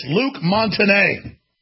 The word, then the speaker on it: Luc Montagnier: There's no teleprompter here, but I did ask the crew how to pronounce it, and it's Luc Montagnier, for the French listeners out there, insulted by me butchering his name.